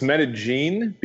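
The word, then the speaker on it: Medellín: So it's Medellin because in Colombia, the way they speak Spanish is they pronounce it the double L as a J. Medellin would be pronounced like that if you were in Mexico or I'm trying to think. A lot of other South American, Central American countries would pronounce it a double L with a Y.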